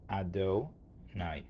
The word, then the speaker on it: Adonai: But the name of Hashem is always pronounced Adonai.